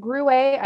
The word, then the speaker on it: Gruet: I got the Gruet. I don't know if that's the correct spelling. – Yeah, I think I'm pretty sure that's like the T is silent.